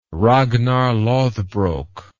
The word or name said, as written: ragnar lodbrok